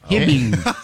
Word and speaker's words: Hibbing: You pronounced it Hibbing. – Hey. – Hibbing. – Where is that? – Minnesota. In Hibbing, Minnesota.